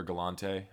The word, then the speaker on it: Galante: You ever watched Forrest Gallant? Or Galante, I think is how you say his name.